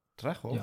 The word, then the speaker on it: Trejo: JJ Jojo? – Was? – Ja, ich glaube, du hast deinen Namen falsch ausgesprochen. Versuch's nochmal. – Trejo? – Ja, so.